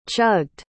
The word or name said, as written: chugged